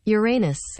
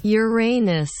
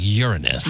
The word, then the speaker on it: Uranus: This is the official pronunciation of Uranus. – Uranus. – Thank you. – Hang on. Let me slow it down. – Uranus. – So that's how you say it. But all these science people are like Uranus.